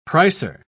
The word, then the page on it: pricer: British English